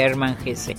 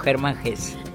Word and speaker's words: Hermann Hesse: No se dice Hermann Hesse, sino Hermann Hesse.